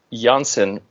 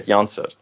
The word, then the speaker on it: Janssen: And he put out this statement that, you know, if you get vaccinated, in Dutch, Janssen rhymes with dancing. So you can go dansen met Janssen.